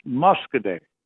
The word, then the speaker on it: Muscoda: That's pronounced Muscoday. – Muscoday. I get this wrong every time. You keep correcting me, and one of these days I'll get Muscoday right.